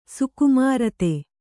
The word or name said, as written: ಸುಕುಮಾರತೆ